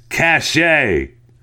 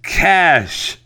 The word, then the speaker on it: cache: In your 712 news story about a cachet of arms, which is what I continue to call it, it's a C-A-C-H-E of arms, you repeatedly pronounced it cachet. The pronunciation of the word is is cash. Another pronunciation of cash is amorphophallus. I'm sorry I got you upset over that. Okay, I got it. C-A-C-H-E is pronounced cash.